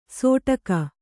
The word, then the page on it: ಸೊಟಕ: Kannada